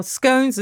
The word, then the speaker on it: scone: Or scones and scones and butter and cream. Which way around? And not butter, jam or cream. – Well, the correct pronunciation is scone, as in gone.